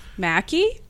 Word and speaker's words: Maki: Today we're joined by Brian Maki. – Yep. – No, I always second guess. I always second guess your last name, even though I know that that's how it's pronounced.